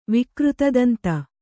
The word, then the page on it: ವಿಕೃತದಂತ: kannada